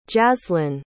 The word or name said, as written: jazlynn